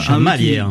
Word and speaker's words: Chamalières: J'espère que je prononce bien. – Chamelières. – Chamelières, pardon, j'avais oublié Chamalière. – Un mot qui traînait. Chamelières. – Chamelières. – Oh, bah, décidément, la troisième sera la bonne.